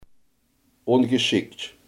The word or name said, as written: ongeschikt